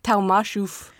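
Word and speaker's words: Tomaszów: 7 days after the Germans seized Talmashof. – Say that again. – Talmashof. – Talmashof. – So it's spelled Tomaszow, but that's it's pronounced Talmashof.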